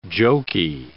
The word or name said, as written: jokey